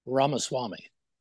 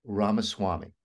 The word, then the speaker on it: Ramaswamy: Vivek rhymes with cake, Ramaswamy. Vivek Ramaswamy.